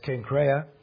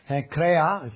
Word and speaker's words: Cenchrea: So it's Cenchrea. Cenchrea, if you want to roll the R and pronounce it the way the Greeks do.